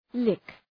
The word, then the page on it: lick: lık